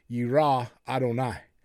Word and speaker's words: yirah Adonai: Now, again, it's pronounced in the fear of the Lord being yirah Adonai.